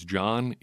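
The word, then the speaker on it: Jon: All I get as John is misspellings. All I get as John Meerdink is misspellings, mispronunciations, whatever, of both first and last names.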